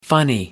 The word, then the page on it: funny: American English